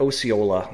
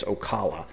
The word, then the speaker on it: Ocala: I called it Osceola last time, and he's saying it's Ocala. So we're going to go with Ocala.